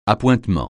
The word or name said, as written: appointements